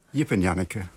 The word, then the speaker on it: Jip en Janneke: Zeg ik het goed, The Complete Jip en Janneke, of is het dan Jip en Janneke? – Jip en Janneke. – Jip en Janneke, zo ja. Is het gewoon gebleven. Maar hoe zou een Engelsman het uitspreken? – Nou, we hebben een note on pronunciation voor in het boek. En dat staat, the Dutch name Jip is pronounced Jip. Dat schrijf je dan met de Griekse I.